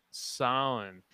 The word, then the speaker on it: Samhain: How do you Sam Hain? Is that how you so? – Pronounce that's it? how it, it would released. would be pronounced phonetically, but it's actually hmm. pronounced Samhain.